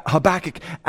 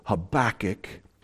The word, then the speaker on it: Habakkuk: If you are a good Jew pronouncing this in Hebrew, you would put the emphasis on the last syllable and you would pronounce it Habakkuk. It's not, you don't have a kook or you don't have a cookie, it's Habakkuk. It's got that on the back of your throat. It's a chait.